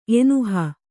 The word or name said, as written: ಎನುಹ